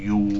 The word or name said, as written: yu